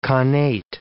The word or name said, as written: connate